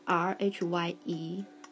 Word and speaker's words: Rhye: 它的拼法是 R H Y E，念成 Rhye。我有收藏他们的第一张作品，那时候台湾有进，所以我就买了，因为他们音乐也是非常的舒服的。那 Rhye 呢，原本是两个音乐制作人组成，由加拿大籍的 Michael Milosh 和丹麦籍的 Robin。